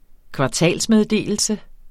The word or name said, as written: kvartalsmeddelelse